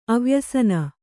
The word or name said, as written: ಅವ್ಯಸನಿ